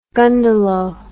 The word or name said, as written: gundalow